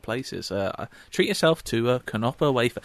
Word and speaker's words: Knopper: Just before this podcast, I treat myself to another one of those knoppers, which I've been actually told now you do pronounce the K. It's not a knopper, it's a knopper.